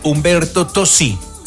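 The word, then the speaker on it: Umberto Tozzi: Hoy tenemos en esa sección un tema de Humberto Tosi.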